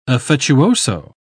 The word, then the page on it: affettuoso: English; British English